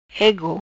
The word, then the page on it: Egu: Patua